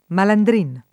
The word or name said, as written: malandrino